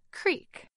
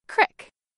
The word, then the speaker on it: creek: Most Americans say this word as creek, with a long E sound, like in the word beet. But in some parts of Appalachia, like western Pennsylvania, West Virginia, and the Ohio Valley, you might hear this word with an I sound, as in crick, like in the word tip.